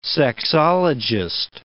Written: sexologist

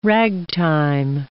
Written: ragtime

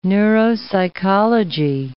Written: neuropsychology

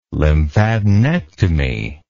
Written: lymphadenectomy

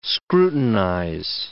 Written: scrutinize

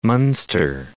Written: Munster

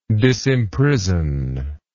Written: disimprisonment

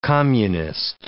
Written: communist

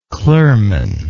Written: clurman

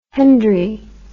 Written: hendry

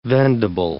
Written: vendable

